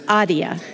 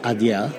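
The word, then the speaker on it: Adeia: Yeah, but it's pronounced ADIA. – Yeah, it is ADIA. – ADIA. – That's how we pronounce.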